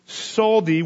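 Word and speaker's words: saldi (צדי): The Hebrew letter that starts each of those eight lines is pronounced saldi, which is roughly equivalent to our T and Z combined.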